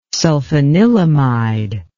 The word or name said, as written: sulfanilamide